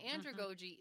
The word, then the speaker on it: andragogy: And in your paper, you had a section on how adults learn, and you used the word andragogy. What's that? Did I pronounce it correctly? – You did, andragogy.